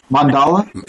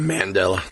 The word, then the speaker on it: Mandala: And the other title that I butchered last show. – Mandala? – Yeah, I kept saying Mandela.